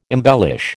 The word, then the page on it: embellish: American English